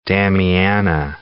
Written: damiana

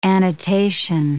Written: annotation

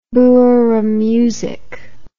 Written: boeremusiek

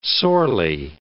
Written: sorely

